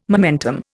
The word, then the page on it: momentum: Indian